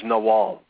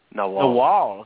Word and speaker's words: Nagual: He's one of their lead facilitators, the Nagual. Is it Nagual? Am I pronouncing it right? – The word is Nawal. – Nawal, okay, it's like a W, okay.